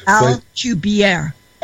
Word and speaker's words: Alcubierre: That he also wrote a paper now that the bubble's not as important as it appeared to be, as far as using Abercrombie. If I don't say it right, you can correct me. Drive Alcubierre.